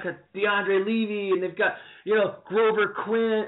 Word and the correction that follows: Glover: or Glover. Or how do you say his name? Anyway, it doesn't matter. – Sure, Glover. – Glover Quinn, that's awesome.